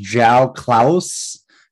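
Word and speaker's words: João Klauss: It's actually pronounced João Klaus, according to St. Louis City's website, which, by the way, their website, their whole app and production team, just very on point.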